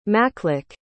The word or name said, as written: makluk